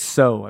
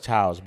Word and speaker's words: Tso: It's so, I believe. – Everyone says Tso's, but it is so. – It's T-S-O. I believe the T is silent.